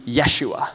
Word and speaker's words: Yeshua: And the actual pronunciation would be Yeshua.